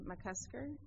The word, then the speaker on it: McCusker: It's Christine McCusker, and the last name is spelled M-C-C-U-S-K-E-R.